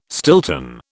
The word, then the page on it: Stilton: British English